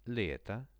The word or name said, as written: leto